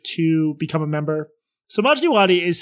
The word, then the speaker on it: Majdi Wadi: It's called Honestly with Barry Weiss, and her first interview was with a guy named Majid Wadi. Majid Wadi? How do you pronounce it? – Majdi Wadi. – Majdi Wadi. – Yes.